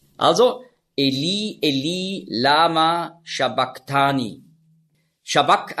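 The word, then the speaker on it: sabachthani: Und das CH im Griechischen, das ist ja ein Buchstabe, das Chi, das hat man ausgesprochen so wie ein C hinten im Gaumen. K. Also nicht Shabbach, sondern Shabbak.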